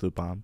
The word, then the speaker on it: Lupin: But Lupin, in Japanese it's Lupin.